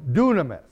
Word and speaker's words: dunamis: This English word, power, that is translated from a Greek word, is pronounced dunamis.